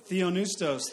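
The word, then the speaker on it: Theonoustos (θεόπνευστος): Theonoustos. That's right, because the P is silent.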